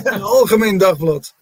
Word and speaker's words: Algemeen Dagblad: I hope I pronounced that right, from Algemeen Dagblad. Is that right? – Yeah, Algemeen Dagblad.